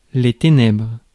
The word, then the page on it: darkness: /ˈdɑːk.nəs/